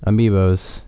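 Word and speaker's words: amiibo: Okay, and by the way, we're going to have to talk about your amoebas. – Amiibos. – Amoebas. – You need to say it right.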